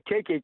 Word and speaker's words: Kiki: Cheeky. – No, Kiki. – Kiki. Oh, yeah, like K-I-K-I. Yeah, yeah. – Yeah, that's it, yeah. – All right. – I'll tell Dan just to go with Kiki in the home straight if it comes into a close finish.